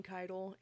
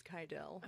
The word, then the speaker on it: Keidel: And I am going to tell you the tragedy of the Keidel family. – Keidel. – I had to literally spell it out because I kept saying Keidel. It's Keidel.